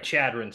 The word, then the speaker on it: Chadron: No, Shedron State. Indiana travels to Nebraska. – If you can't say it right, you don't get it. – Sorry, Chadron State.